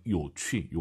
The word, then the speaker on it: 有趣: 하지만 여러분들께서 요취, 요것도 많이 씁니다. 왜 가져왔는지 아세요? 취, 취 발음만 들으면 우린 역시 가라를 많이 떠올려요. 취, 똑같은 발음이죠.